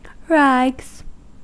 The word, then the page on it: rags: American English